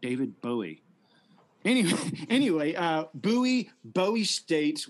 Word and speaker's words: Bowie State: It's actually pronounced Bowie State. There's some confusion, but yeah, it's like, Bowie State.